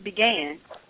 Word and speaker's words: Bigen: Look, it's not Bigen. It's not Beijing. It's Bigen. Okay? That's the proper pronunciation of the word.